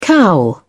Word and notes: cowl: British English

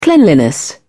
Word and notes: cleanliness: British English

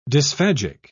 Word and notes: dysphagic: British English

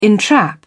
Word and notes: entrap: British English

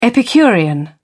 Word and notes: epicurean: British English

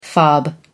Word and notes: fob: American English

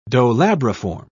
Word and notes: dolabriform: British English

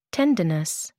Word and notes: tendinous: American English

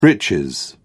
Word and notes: breeches: British English

